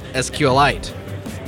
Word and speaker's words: SQLite: We also have interviewed Richard Hipp of SQLite, and we learned there that he likes to call it SQLite. Like a meteorite.